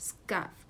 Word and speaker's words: scuff: 第一个 scuff， S C 组合的啊，嗯， scuff，小朋友会发现它的读音呢，哎，由 k 清辅音变成了浊辅音 g，是吧？